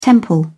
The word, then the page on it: temple: British English